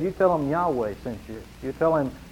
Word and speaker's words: Yahweh: The word "O Lord," the word for Lord there, is the word Yahweh, sometimes pronounced Jehovah.